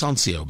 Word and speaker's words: Sonsio: It's pronounced Sancio, by the way, S-O-N-S-I-O, the Sancio Grand Prix.